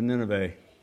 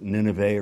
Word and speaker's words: Nineveh: Nineveh. At least my Syrian friends tell me I'm supposed to pronounce it Nineveh rather than Nineveh.